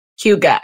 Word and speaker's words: hygge: Hygge, and it's a Danish word. – Yes. – And for the listeners out there, it's the word that's spelled H-Y-G-G-E.